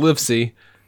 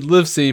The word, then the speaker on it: Livesey: But the surname is Livesey. Sorry, Livesey, pronounced live as in I live in a house, and C.